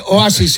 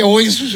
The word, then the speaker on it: Oasis: No se dice Oasis, se dice Oasis.